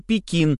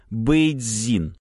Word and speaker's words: Пекин: Так и слова «северная столица» мы вслед за жителями севера Китая произносим «Пекин», а большинство западноевропейцев вслед за жителями юга – «Бэйцзин».